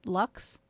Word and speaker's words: Luxe: This one is made by, I guess you would pronounce it Lux, like luxury. It's spelled L-U-X-E.